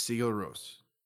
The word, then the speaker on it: Sigur Rós: It's Sigur Rós.